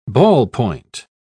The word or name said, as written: ballpoint